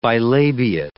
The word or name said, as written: bilabiate